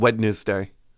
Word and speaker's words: Wednesday: Also, I decided I'm going to start pronouncing a hard D in Wednesday.